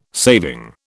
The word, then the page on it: saving: British English